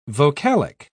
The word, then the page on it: vocalic: British English